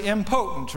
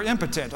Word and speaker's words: impotent: A lot of people say impotent, right? A lot of us think we're impotent, but no, we're impotent, okay? That would be the right way to say it.